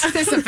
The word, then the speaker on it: Sisyphus: How do you say Sisyphus? – What word are you saying? – Brewing. – Sisyphus. – I can't use half my neck muscles to use my voice. – Sisyphus.